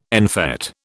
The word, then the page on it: en fête: British English